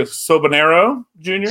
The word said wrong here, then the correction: Soberano: Soberano. – Soberano. Yeah. Sober, like as in sober as in I don't drink.